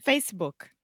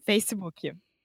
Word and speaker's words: Facebook: So let me just point out two pronunciation things. First, the most important thing is what we call in linguistics the vogal de apoio. So you don't want to say Facebook.